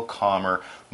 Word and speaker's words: calm: It's spelled C-A-L-M. Yes, that's right. C-A-L-M. But it's pronounced calm, not calm. That'd be weird.